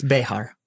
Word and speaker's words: Bexar: It shouldn't be Bear County. That's not Bear. – It's Behar. – Yeah. – It's actually how it's pronounced.